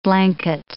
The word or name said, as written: blanket